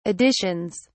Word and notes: editions: British English

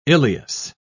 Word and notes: ileus: British English

